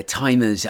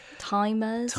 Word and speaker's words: Tymurs: Timers. – This is spelled T-Y-M-U-R-S, as in Tylenol murders. – Not as in old timers.